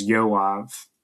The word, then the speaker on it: Joab: So Joab could also, that was the thing, the B on the end of Joab. You could also pronounce that name as Yoav.